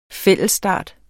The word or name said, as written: fællesstart